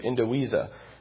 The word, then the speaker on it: indivisa: The V is pronounced like a W, indivisa.